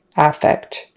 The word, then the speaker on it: affect: But the noun is with the stress on the first syllable, affect.